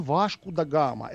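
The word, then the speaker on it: Vasco da Gama: Васка, да, что, он, что за Василий такой, да, он Вашку Дон Вашку да Гама. Это же порту, порту, порту, португальское произношение.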